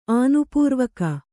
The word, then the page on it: ಆನುಪೂರ್ವಕ: ānupūrvaka